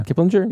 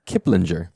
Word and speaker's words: Kiplinger: Kiplinger. – Yeah. I feel like I never am certain. – You gotta be confident. – Yeah, Kiplinger.